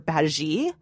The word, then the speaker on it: Badguy: And they have a new tour manager played by Ricky Gervais playing Mr. Badgy. His name is pronounced spelled B-A-D-G-U-Y. Mr. Bad Guy. Badgy, okay.